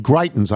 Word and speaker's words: Greitens: Greitens, I believe, am I pronouncing that right? – Yep.